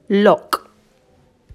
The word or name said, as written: Lough